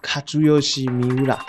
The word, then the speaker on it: Kazuyoshi Miura: 三浦知良的名字日文念作 Kazuyoshi Miura，因此喜欢他的球迷给他一个 King Kazu 的称号，而他的庆祝动作就被称为 Kazu Dance。